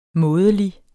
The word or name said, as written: mådelig